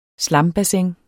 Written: slambassin

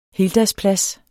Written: heldagsplads

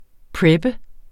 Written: prepper

